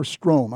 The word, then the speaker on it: Strohm: And to help Dave and me, we have a guest who is Deacon Keith Strohm, S-T-R-O-H-M. And Keith, is it Strohm or Strohm? I always want to tell me which is it. – No, it's Strohm. You actually always pronounce it correctly.